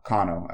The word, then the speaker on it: Kano: But it's like they also had like they had in mind that he was like half Japanese because his name's Kano or Kano as a, uh, how it would be pronounced in Japanese.